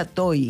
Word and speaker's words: Tohi: T-O-H-I. TOY. – Ah, T-O-H-I. – Sí. – Como Tojí. – Sí. – Tojí, y se pronuncia TOY.